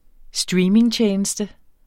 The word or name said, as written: streamingtjeneste